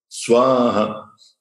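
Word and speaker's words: Svāhā: Burbuva, suaha, Swaha, veja que sobe.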